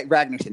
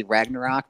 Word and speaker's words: Ragnarsson: Uh, yeah, Ragnarsson. That's it. I keep wanting to say Ragnarok, but obviously that's it.